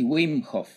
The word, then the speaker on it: Wim: El tema tuyo que ya nos va a compartir, a mí cuando, cuando me pareció esto del método Wim ¿está bien pronunciado así, Wim Sí, Wim Perfecto.